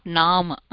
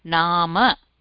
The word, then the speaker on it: नाम: Here we have to use when we pronounce the word nama we have to use the complete pronunciation, not naam. Naama.